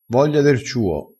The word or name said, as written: voglia der ciuo